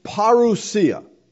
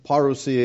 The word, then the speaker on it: parousia: Now, coming here is the Greek word parousia. I know you could say that however you want, but that's how it's pronounced in the Greek. Parousia is what most people say. Parousia is the Greek.